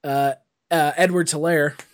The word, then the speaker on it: Edwards-Helaire: So it's scary to the rest of the NFL that the Chiefs actually got better with their rookie running back, Edwards-Helaire.